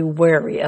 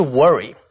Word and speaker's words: wary: For example, you should teach your son to be wary of strangers. – That's good advice. 注意这个字, W-A-R-Y, 读作 wary, 不是 worry. Wary 是小心谨慎的。